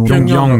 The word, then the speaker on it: Pyongyang: c'est un projet de film en fait d'adaptation d'une bande dessinée qui s'appelle Pyeongpang, qui est, euh, si je prononce bien, je c'est mm. du nom. – Pyongyang, Pyongyang pardon.